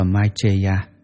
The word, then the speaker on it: Maitreya: tiếng Ấn Độ đọc là Maitreya.